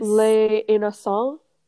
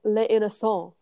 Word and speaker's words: Les Innocents: Les Innocents. – Oh, like the innocent. Yes, yes. – Yeah, so it's like the innocence, but it's Les Innocents.